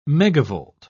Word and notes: megavolt: British English

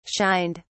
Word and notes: shined: British English

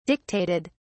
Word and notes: dictated: British English

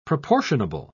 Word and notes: proportionable: British English

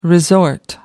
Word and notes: resort: American English

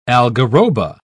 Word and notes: algarroba: British English

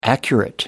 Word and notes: accurate: American English

